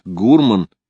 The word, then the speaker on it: Гурман: Из этих гурман, ударение на первом слоге, был толстый, лысый человек с кофейным родимым пятном в пол черепа, большими покатыми плечами и презрительно обиженным выражением на толстых лиловатых губах.